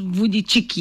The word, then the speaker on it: Vujicic: Vujicic, oh yes, pronounce as Vujicic, yes, Vujicic, okay.